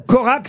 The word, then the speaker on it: Korak: De Gojac? – Non, Corac, ça s'écrit K-O-R-A-K.